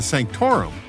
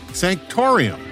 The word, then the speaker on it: Sanctorum: Apparently, I added the letter I to Sanctorum, saying instead, Sanctorium.